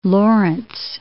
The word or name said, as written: Laurence